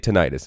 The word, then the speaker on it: tinnitus: Maybe you want to have tinnitus? Is that how you pronounced it earlier, Dr. Ivory? Because I always say tinnitus, and I guess that's wrong. – Tinnitus, tinnitus, tomato, tomato, doesn't matter.